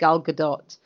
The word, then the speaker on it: Gal Gadot: So until I've sort of seen their performance, in the same way as I've not watched Gal Gadot in any interviews. And yes, I will always pronounce it the Hebrew way.